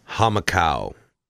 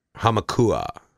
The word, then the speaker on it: Hamakua: Hamakau. Hamakua.